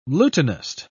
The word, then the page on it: lutanist: British English